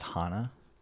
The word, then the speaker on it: Hana: It's pronounced Hana.